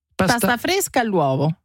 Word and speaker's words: pasta fresca all'uovo: Paste fresca all'uovo. J'étais bien parti. Je sentais que c'était bien. – Pasta all'uovo. Pasta fresca all'uovo.